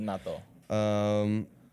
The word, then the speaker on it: Mattia Binotto: Mattia Binotto? – We were trying to say his name before we started. Yeah. I didn't mean to put you on the spot. – Mattia Binotto.